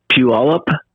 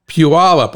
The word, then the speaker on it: Puyallup: How do you pronounce it? Polyup? Polyup? – Oh, Puyallup? – Puyallup. Oh, Pew, like Pew, it stinks. Puyallup.